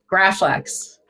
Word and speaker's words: Graflex: This is how do you pronounce it? Graflex? Graflex? – Graflex.